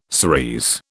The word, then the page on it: cerise: British English